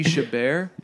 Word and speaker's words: Chabert: And now Lacey Chabert. Lacey, I think it's Lacey Chabert.